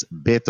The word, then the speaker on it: Beto: Well, first of all, it's Beto O'Rourke. – Well, that's the proper Beto pronunciation.